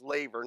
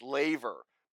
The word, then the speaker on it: laver: Now, this represents like a circle, just like the laver, by the way, that was the correct pronunciation for it is laver, not laver. I've been in a habit for 25 years. I can't get out of it. It's laver.